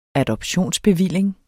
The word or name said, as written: adoptionsbevilling